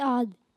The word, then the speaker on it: عاد: ابھی آپ کے پاس نہیں جانا میں نے۔ جی سورہ عاد،